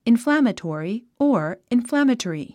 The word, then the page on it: inflammatory: inflǽmətɔ̀ːri; インフラァマトォリィ